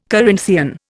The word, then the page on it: Corinthian: Indian